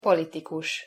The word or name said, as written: politikus